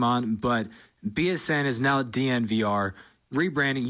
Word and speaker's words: DNVR: So that's what the DNVR is, and it is pronounced DNVR, but it's Denver without the E's.